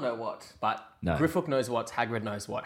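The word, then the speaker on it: Griphook: Is it pronounced Grip Hook or Grip Hook? – Griffook.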